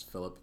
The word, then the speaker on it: Philip: My name is Philip, P-H-I-L-I-P. Last name is spelled E-M-M-E-R-T-O-N.